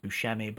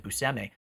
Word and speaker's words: Buscemi: But you're right, the Steve Buscemi job, or rather the time I worked with him, and I learned, I also thought his name was pronounced Buscemi, but apparently he pronounces it Buscemi. I learned that when I worked with him. Yeah, it's funny. It's like everybody, culture has put the Shemi on him, but it's really Semi.